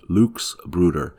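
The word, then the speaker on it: Luchsbruder: And this is where I found this um, description of the Luke's Bruder uh, and again, it's spelled L U C H S and not Lucas Bruder like St. Luke.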